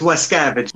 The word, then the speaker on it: Wascavage: Et c'est écrit, produit, filmé, monté avec la musique, les costumes et les effets spéciaux de Dave Vascavage. – Je pense que c'est Wascavage, le W, je pense que ça se prononce Wascavage. – Ah, pour vrai? – Wascavage? No, it's Wascavage.